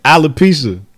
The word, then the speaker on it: alopecia: Call this shit Appalachia. – How you say it? – Alopecia. – Alopecia? Mm-hmm.